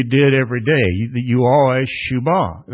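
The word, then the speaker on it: shuwbah: In Hebrew, the word is shubah. If you want to take notes, it's S-H-U-W-B-A-H. You pronounce it shubah.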